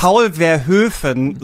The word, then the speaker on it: Paul Verhoeven: Paul Verhoeven so schön als Aussprache dieses Namen.